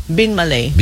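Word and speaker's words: Binmaley: Binmalay ba yung bigkas noon? – Ano ba dapat? – Hindi, natanong ko nga. – Binmalay. – Hindi ba Binmalay? – Gusto ko Binmalay. – Hindi sa gusto mo. Kung anong tamang bigkas. Hindi lang yung kung anong gusto mo. Gusto ko kasi tama palagi. Kasi lugar nila yun. Eh, no? Okay. So ano bang tama? Binmalay o Binmalay? – Binmalay.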